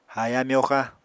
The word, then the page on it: haaya-myoxa: Apatani